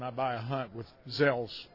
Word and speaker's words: Gsell's: Gsell's is spelled, and in case you want to look us up online, it's G-S-E-L-L-S. Gsell's, it's pronounced like it's a Z, but it's a G-S-E-L-L.